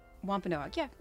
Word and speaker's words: Wampanoag: Wampanoag? – Wampanoag. – Wampanoag? – Wampanoag.